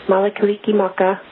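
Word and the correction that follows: Mele Kalikimaka: And yes, mele kaliki maka to you.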